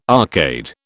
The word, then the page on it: arcade: British English